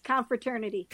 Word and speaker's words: confraternity: The chief exorcist for the confraternity. Did I say that right? – Yes, confraternity. – Confraternity. Yes. It's two words that make one word, and that's clearly confusing me.